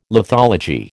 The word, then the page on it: lithology: American English